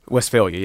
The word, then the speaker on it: Westphalia: In Prince George's County's Westphalia, is that how it's pronounced? – Westphalia, yep. – Westphalia correct.